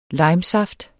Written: limesaft

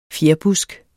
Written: fjerbusk

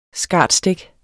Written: SCARTSTIK

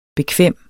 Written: bekvem

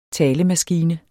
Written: talemaskine

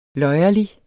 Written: løjerlig

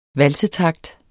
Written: valsetakt

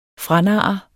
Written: franarre

